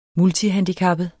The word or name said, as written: multihandicappet